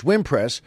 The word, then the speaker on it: Wimpress: And it's so funny that then Gordon wrote in the letter, it's the last name is Wimpress, which he said is pronounced like impress, but he said with the W, and then he said, but it's actually pronounced with the accent on the first syllable, so it's Wimpress,